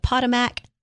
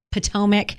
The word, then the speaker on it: Potomac: The 6th season premiere of The Real Housewives of Potomac. Oh, I said that wrong. Potomac, and on Discovery.